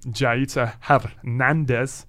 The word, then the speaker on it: Jaiza Hernandez: And then we are also joined by a new We Love Philly student, Jaiza Hernandez. – Hello. – How are you? Did I say it right? I've been trying to learn Spanish. – Yes, you did.